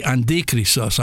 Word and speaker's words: Antichristos: And the Antichristos, I'm not going to call him Antichrist. The non-Protestant way of pronouncing it is Andi. That's the Greek, Antichristos, instead of Christ, a substitute.